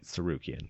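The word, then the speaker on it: Tsarukyan: Sarukian. There's a T in the front. It's difficult. I'm pretty sure it's silent, though.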